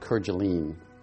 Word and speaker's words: Kerguelen: Today's presentation is on a very remote location in the Indian Ocean, Kerguelen Island. Some pronounce it Kerguelen.